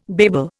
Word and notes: babel: Indian

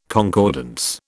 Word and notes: concordance: British English

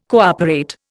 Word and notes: cooperate: Indian

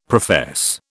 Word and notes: profess: British English

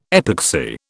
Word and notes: epoxy: British English

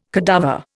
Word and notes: cadaver: Indian

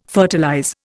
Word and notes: fertilize: Indian

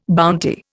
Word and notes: bounty: Indian

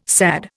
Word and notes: SAD: Indian

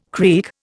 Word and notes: creak: Indian

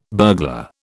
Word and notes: burglar: British English

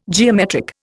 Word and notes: geometric: Indian

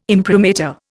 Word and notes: imprimatur: Indian

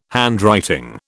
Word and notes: handwriting: British English

